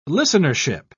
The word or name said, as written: listenership